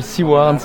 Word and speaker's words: Seward: Alors le groupe, ça s'appelle Seaward, ça s'écrit SEWARD pour la radio.